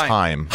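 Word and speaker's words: Haim: At some point, we are going to talk to Dr. Is it pronounced Ham? – I believe it's Heim. – Heim. Dr. Heim.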